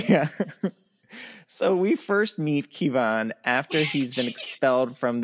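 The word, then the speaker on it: Ciabhán: It's spelled C-I-A-B-H-A-N, or pronounced Kevan.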